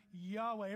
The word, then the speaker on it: Yahweh: And if you see the next slide, here's how it's pronounced. Yahweh. Everybody say Yahweh.